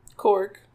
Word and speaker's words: Korg: I enjoyed, I'm going to get his name wrong, Gorg? – Korg. – Korg.